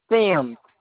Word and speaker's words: Semmes: Sims.